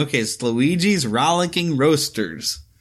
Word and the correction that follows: Roadsters: Roadsters. – Roadsters. – I sound like I said roasters, but it's Luigi's Rollicking Roadsters.